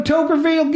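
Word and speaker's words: Toquerville: Let's go to Tokerville. Get some toke, man. It's spelled T-O-Q-U-E-R-ville. Tokerville.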